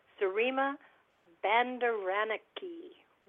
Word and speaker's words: Sirima Bandaranaike: Well, in 1960, in the country of Sri Lanka, known at that time as Ceylon, Sirima Bandaranaike would become the first woman prime minister in the world.